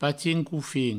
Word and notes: patin-cofin: patin-coufin

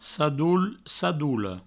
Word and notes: sadol, sadola: sadou, sadoulo

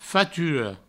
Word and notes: fatura: faturo; Provençal

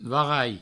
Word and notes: varalhar: varaia

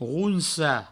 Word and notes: ronsar: rounsa; Provençal

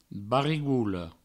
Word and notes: barigola: barigoulo; Provençal

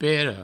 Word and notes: pera: Provençal; pero

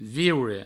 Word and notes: viure: vieure

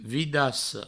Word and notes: vidassa: vidasso

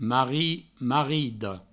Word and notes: marrit: marrida; marrido

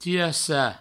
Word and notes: tirassar: tirassa

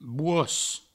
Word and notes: bosc: bouos

